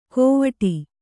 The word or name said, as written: ಗೋವಾಟಿ